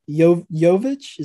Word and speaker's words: Jovic: Tom, do you have any thoughts on Jovic? Is that how you say it? – Yeah.